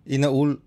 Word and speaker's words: Inaul: Tama ba yung pronunciation ko? Inaul Weaves? – Yes.